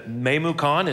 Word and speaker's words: Memucan: So when I get to Memucan, it says May Moo, like a cow, Khan, right? It says that on my paper.